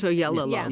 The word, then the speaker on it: Oyelowo: Now, when it comes to acting, okay, David Oyelowo, whose name I always seem to mispronounce. – I think it's Oyelowo. – Yeah.